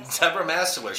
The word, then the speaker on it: Debra Mastaler: Mastaler, like going to church and... Mastaler. – Debra Mastaler.